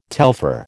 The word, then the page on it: telpher: American English